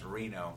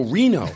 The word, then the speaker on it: Reno: I think it's pronounced Reno. – Oh, Reno.